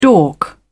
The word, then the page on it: dork: British English